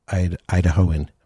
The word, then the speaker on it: Idahoan: Elin Jewell is a local singer-songwriter gone big time, so an internationally touring artist, but a native Idahoan. Am I saying that right? – Mm-hmm. – Oh, good.